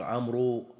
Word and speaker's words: عمرو: بل في العربية حين حروف تنطق ولكن لا تكتب، شوف تنطق وما تكتب، مثل عمرو بعض الناس يقول عمرو يحط واو، هي ليست كذلك.